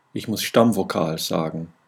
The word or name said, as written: Stammvokal